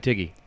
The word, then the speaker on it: Tighe: Kevin Tighe.